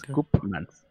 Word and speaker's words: Koopmans: Em, entonces, la doctora Marion Koopmans Marion Koopmans, que se escribe así como Koopa de Mario Bros. Y Mans, Koopmans.